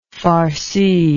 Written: farcing

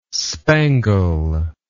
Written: spangle

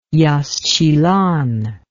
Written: yaxchilan

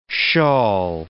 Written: shawl

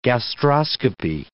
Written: gastroscopy

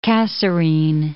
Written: Kasserine Pass

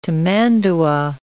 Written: tamandua

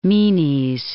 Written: Menes

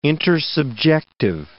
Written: intersubjective

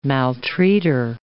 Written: maltreater

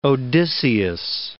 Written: Odysseus

